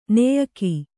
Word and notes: ನೆಯಿಗೆ: kannada

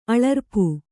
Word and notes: ಅಳರ್ಪು: aḷarpu

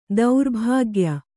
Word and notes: ದುರ್ಭಾಗ್ಯ: durbhāgya; Kannada

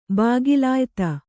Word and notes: ಬಾಗಿಲಾಯ್ತ: Kannada

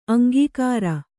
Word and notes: ಅಂಕಕಾರ: aŋkakāra

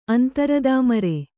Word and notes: ಅಂತರದಾಮರೆ: kannada

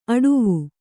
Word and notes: ಅಡುವು: aḍuvu

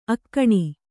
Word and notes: ಅಕ್ಕಣಿ: kannada

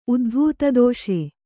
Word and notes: ಉದ್ಧೂತದೋಷ: uddhūtadōṣa

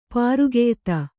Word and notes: ಪರಕೀಯತೆ: Kannada

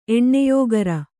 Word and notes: ಎಣ್ಣೆಯೋಗರ: eṇṇeyōgara

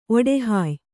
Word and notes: ಒಡಹಾಯ್: oḍahāy

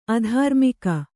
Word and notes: ಅಧಾರ್ಮಿಕ: adhārmika

